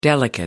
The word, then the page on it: delicate: American English